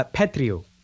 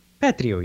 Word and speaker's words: Petriw: It's uh, Petriw. – Petriw. Uh, Petriw, yeah.